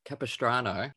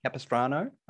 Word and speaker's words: Capistrano: The Curse of Capistrano? – Capistrano. – Capistrano, okay.